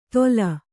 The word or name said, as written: ತೋಲ್